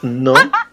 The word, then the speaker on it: gnome: It is pronounced gnome. But that is a... – Is my book wrong? There was a letter G. – The G is silent, darling.